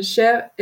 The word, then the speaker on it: Schar: Cher, S-C-H-A-R mais sur Instagram, c'est AER.